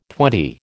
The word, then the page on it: twenty: American English